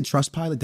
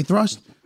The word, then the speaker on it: Trustpilot: Guys, did I say Trustpilot? Trustpilot, my fault. If I said that wrong, I apologize. – Trustpilot? – Trust. Did I say thrust? – Yeah, nah, I don't know. – Trust pilot.